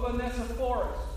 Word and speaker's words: Onesiphorus: He says, "Greet Prisca and Aquila and the household of Onesiphorus." That was a good pronunciation of that, by the way.